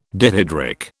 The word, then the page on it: dihydric: British English